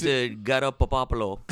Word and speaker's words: Garoppolo: It's Garoppolo.